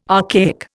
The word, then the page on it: archaic: Indian